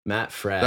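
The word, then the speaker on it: Fradd: I was going to say his name, Matt Fraud. Fred. I don't know why for some reason. – Not Matt Fraud. – Yeah, I look at it and I say, Fred. Matt Fred.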